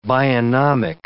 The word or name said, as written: bionomic